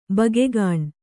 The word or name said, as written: ಭೊಗ್ಗನೆ